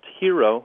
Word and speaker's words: Jiro: It was a J-I-R-O? – J-I-R-O, but it's pronounced Hero, Dreams of Sushi.